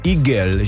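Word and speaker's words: Hugel: Stejně to je jako třeba, jak teďka hrajeme toho Hugela, tak on to je Francouz a pravděpodobně se jmenuje Igel, že? – No.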